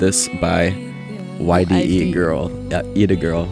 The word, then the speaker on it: Yde Girl: Yeah, we have to issue a correction, as it's pronounced Ida Girl.